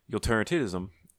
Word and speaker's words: utilitarianism: When it comes to utilitarianism, I keep saying that wrong.